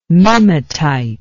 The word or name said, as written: mimetite